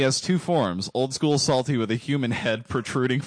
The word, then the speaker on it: Psalty: Psalty, that's P-S-A-L-T-Y, the Singing Songbook. Psalty, pronounced like Psalms and Psalty in parentheses.